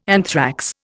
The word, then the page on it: anthrax: Indian